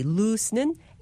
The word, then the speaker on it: loose: 발음할 때 L-O-O-S-E, lose는 S로 끝납니다. 반면에 L-O-S-E, lose는 Z로 마무리합니다. Lose.